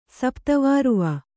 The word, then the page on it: ಸಪ್ತವಾರುವ: Kannada